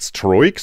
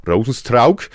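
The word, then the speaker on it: Rozenstruik: So wird er ausgesprochen. Nicht Stroik, Stroik, Strike, sondern Rosenstrauk, wie es der gute Rudi Carell äh, nicht besser hätte sagen können.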